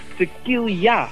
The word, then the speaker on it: tequila: Maar die zei tequila of tequila, hoe moet je dat eigenlijk uitspreken? Want jij weet het daar, hè? – Ja, het is tequila. – Tequila. – Ja, tequila.